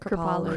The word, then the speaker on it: Kripalu: Kripalu.